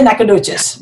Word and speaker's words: Nacogdoches: Nagatoches. – It is Nacog Doches. Doches. – How do you say it? You just say it, Nacogdoches. – Nacogdoches. Or whatever, all those. Huh. You just say Nacogdoches.